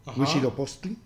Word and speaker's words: Huitzilopochtli: Y nos dice nuestro amigo Curiaki, Huitzilopochtli. – Curiaki Huitzilopochtli. – Que solo así como se escribe es como se pronuncia.